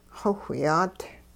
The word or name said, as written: xowhyaat